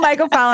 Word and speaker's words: mycophile: Myco? Like Mike, is it M-I-C-O? How do you spell that? – M-Y-C-O-P-H-I-L-E. – Mycophile. Fantastic. Thank you.